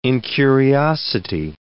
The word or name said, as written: incuriosity